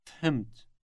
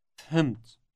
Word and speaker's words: فهمت: The word starts with an F sound, followed immediately by an H sound, as in Henry. Listen and repeat. – فهمت. فهمت.